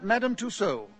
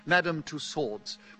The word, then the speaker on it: Madame Tussaud: Madame Tussaud, or, to use the common anglicised pronunciation, Madame Tussauds.